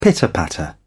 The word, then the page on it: pitter patter: British English